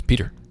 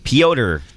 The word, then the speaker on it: Pyotr: It's Peter. – No, it's not. – Pytor. – Pyotr Kochetkov. – Pyotr. Okay, whatever. All right. – Pyotr Kochetkov.